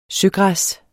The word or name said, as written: søgræs